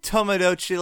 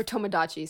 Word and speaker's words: Tomodachi: Fucking Tomodachi Life. – Or Tomodachi, sorry. – Yeah, whatever. We can mispronounce it because it's a bad game.